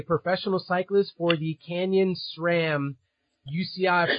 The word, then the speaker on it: SRAM: For those of you that use SRAM components, there is no H in between the S and the R.